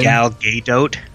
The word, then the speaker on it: Gadot: Isn't her name Godot too, or Godet or whatever? – I just don't know. I try not to say her name because I'm sure I'm going to mispronounce it. – Okay, Wonder Woman. – Gal Gadot. – Let me see. – I think that's right. – Yeah, it's Gal Gadot.